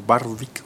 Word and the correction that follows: Dionne Warwick: no sé cómo se pronuncia. – No, Dionne Warwick.